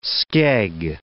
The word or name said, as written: skeg